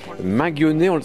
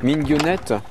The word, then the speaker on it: Minguenet: On est toujours à Gourin sur RCF, au manoir de Minguenet. Ça, c'est la prononciation française, mais on peut dire aussi Minguenette. – Alors, Minguenette, mais il faut être breton pour bien le prononcer. Moi, j'en suis incapable, j'ai honte. Mais eux, ils le disent plutôt comme Minguenette, mais je le dis mal.